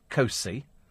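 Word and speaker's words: Costessey: Costessi is pronounced Cosy.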